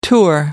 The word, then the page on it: tour: American English